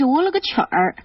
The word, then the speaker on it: 我了个曲儿: 嗯，真的是这样，好像刚刚我的发音不是特别正确，我说的是我了个去，好像现在看看是我了个曲儿。